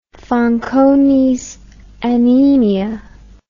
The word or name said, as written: fanconis anemia